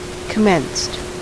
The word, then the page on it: commenced: American English